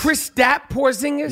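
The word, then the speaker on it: Kristaps Porziņģis: Is it Kristoff? – KP, man. – No, but I got to say his name Porzingis. – Right. Chris Stapp Porzingis? – Yeah. He got it.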